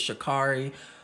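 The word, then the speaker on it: Sha'Carri: So like it, I really hope with Shikari, and I hope I'm saying her name right.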